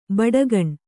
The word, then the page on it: ಬುಡಿಗಾನೆ: kannada